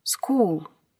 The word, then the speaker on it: school: School. Школа. Примечание. В этом слове буквосочетание CH читается как «к», так как это слово греческого происхождения. Аналогично читаются и другие слова с этим же корнем.